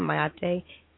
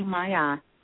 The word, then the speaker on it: Maat: Am I saying it right? Maate? M-A-A-T. – You can say it that way. Some people say simply Maya, but yes, you can say it that way.